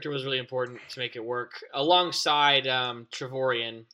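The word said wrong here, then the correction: Trevean: Yeah, I'm sorry, Trevian. Trevian.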